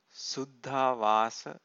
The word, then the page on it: suddhavasa: Pali